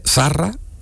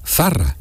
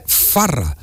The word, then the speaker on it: Zarra: Entonces Zarra es con Z, Zarra. Y él gritó y al mundo entero el segol de Zarra y decía Farra, porque cambiaba la Z por la F.